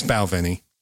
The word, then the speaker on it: Balvenie: It's Balvenie.